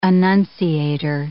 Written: annunciator

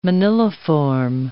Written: moniliform

